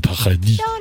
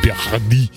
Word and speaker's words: Paradis: Vanessa Paradis. – But you have to say it like the French do. – Okay. – Vanessa Paradis. Paradis.